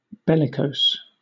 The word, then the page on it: bellicose: British English